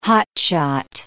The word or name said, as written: hotshot